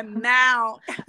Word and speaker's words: Mount: E não é Mon Vernon. – Não, é Mount.